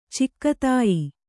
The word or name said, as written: ಚಿಕ್ಕತಾಯಿ